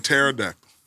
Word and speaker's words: pterodactyl: Shaw just told us that it's a P in pterodactyl.